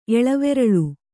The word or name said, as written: ಎಳವೆರಳು